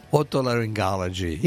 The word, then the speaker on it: otolaryngology: He's the medical director, board-certified otolaryngology. I never say that right. – Otolaryngology, yeah.